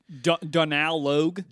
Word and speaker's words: Donal Logue: Is Donald Logue, am I saying that right? – I always pronounce it as Donal Logue.